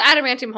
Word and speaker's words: adamantium: And it's called Adamantium Holdings. I don't know if it has any relation, but it's spelled A-D-A-M-A-N-T-I-U-M. That's adamantium, right? – Yeah, yeah.